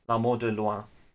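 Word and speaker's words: L'Amour de loin: That's L apostrophe A-M-O-U-R, new word D-E, new word L-O-I-N, L'Amour de Loire.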